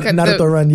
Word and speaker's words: Naruto: So the meme or the initial plan was for people to Naruto run. Am I saying that right? You know, like Naruto run.